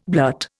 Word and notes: blurt: Indian

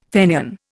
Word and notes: Fenian: Indian

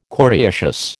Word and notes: coriaceous: American English

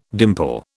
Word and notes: dimple: British English

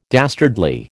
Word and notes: dastardly: American English

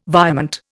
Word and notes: virement: Indian

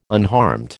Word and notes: unharmed: American English

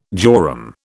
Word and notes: jorum: British English

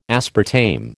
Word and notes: aspartame: American English

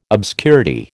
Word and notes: obscurity: American English

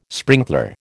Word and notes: sprinkler: American English

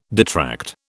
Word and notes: detract: British English

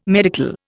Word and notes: medical: Indian

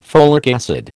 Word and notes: folic acid: American English

